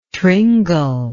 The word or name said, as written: tringle